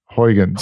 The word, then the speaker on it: Huygens: Is it Huygens? Is that what we say? – I always pronounce it Huygens. – Huygens, I think you're right.